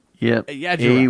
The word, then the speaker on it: Ayyadurai: His name is, how do you say this? Shiva Ayajirai. Is that how you say his name? Shiva Ayajirai. A-Y-Y-A-D-U-R-A-I. Yeah. I'm sure you said it properly.